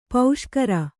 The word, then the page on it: ಪುಷ್ಕರಿ: puṣkari